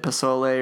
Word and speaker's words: pozole: So the first one is a plant-based pozole soup.